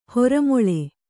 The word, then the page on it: ಹೊರಮೊಳೆ: hora moḷe